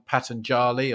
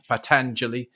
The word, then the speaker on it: Patanjali: A very ancient one, Patanjali, or some pronounce it Patanjali, with his aphorisms.